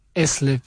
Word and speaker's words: asleep: Asleep. – Asleep. Because there is an A. – All right. It's with the right. – Yes. – Yeah, no. I was mispronouncing it.